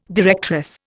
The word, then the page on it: directress: Indian